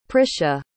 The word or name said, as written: prisha